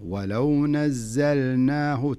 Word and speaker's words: ولو نزلناه: اللام عليها سكون، وسكون ظاهر بين معنى، معناها أنك لابد تنطق اللام. فلذلك لا تقول ولو نزلناه، ولو نزلناه، لا خلها اللام ظاهرة، ولو نزلناه، تنطق اللام ثم تنطق النون.